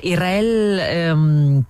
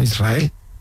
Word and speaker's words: Israel: Israel, ¿no? – Y la S con la R es difícil de pronunciar. La gente dice Israel. Es sin verdad. Que suene la S, Israel, sí, es difícil pronunciar.